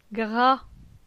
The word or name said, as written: Gra